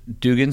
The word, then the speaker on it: Dugan: If you come down, was it Dugan Street? Is that how you pronounce it? – Yeah.